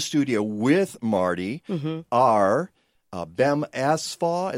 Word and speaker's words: Bem: It's not Ben with an N. It's Bem with an M. Bem.